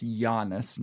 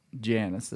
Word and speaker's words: Giannis: Okay, Steve, I've got a quote here about Giannis, pronounced Giannis, not Janus, as I said, mistakenly.